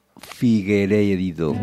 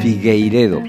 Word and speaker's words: Figueiredo: Seguimos con Corrientes Brasileñas, ahora bajo la firma de Cyril Aimé y de Diego Figueiredo. Figueiredo, perdón, que lo he dicho mal.